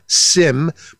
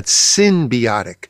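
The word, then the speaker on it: synbiotic: That's spelled S-Y-N as in Nancy, not sim, but symbiotic.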